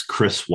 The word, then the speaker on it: Kris: So my name is spelled K-R-I-S, Chris Whitehead.